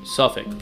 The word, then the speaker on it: Suffolk: 58 Eastern near Suffolk? – Suffolk. – Suffolk. Sorry. It's spelled like that.